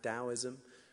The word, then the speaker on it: Taoism: Taoism used to be spelled Taoism with a T, pronounced Taoism.